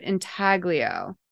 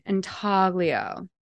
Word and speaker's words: intaglio: The first time Grammy talks about the necklace, she pronounces it intaglio, incorrect. When she takes it back, she pronounces it intaglio. So it's no G. The G is not there.